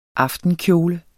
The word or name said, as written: AFTENKJOLE